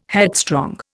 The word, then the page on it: headstrong: Indian